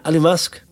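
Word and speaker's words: Alumasc: Alumask.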